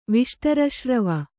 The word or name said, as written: ವಿಷ್ಟರಶ್ರವ